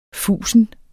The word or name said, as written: fusen